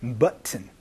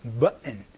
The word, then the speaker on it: button: 여기에서의 button이라는 발음은요, button 이렇게 얘기를 하거나 혹은 button 해서 은 소리로 바뀔 수도 있으니까 요거 조금 주의해 주시면서 연습 한번 해보도록 하겠습니다.